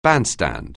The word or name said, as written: bandstand